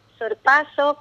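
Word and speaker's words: sorpasso: Dice, aconseja en cuanto a la pronunciación, sorpaso, con acento en la A, y en la ortografía exigen que por ser un extranjerismo debe escribirse con cursiva.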